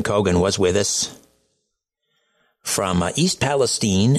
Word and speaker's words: East Palestine: Now, first of all, is it pronounced East Palestine or Palestine? I've heard both. – East Palestine. – It is East Palestine. Okay, thank you for that.